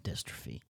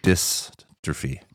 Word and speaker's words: Destrophy: The text you sent me, swear to God, it said Destrophy. – It said Dest with an E. It didn't say Dystrophy.